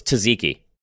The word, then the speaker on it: tzatziki: What's tzatziki? – Tzatziki. That's how I pronounce it.